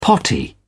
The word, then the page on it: potty: British English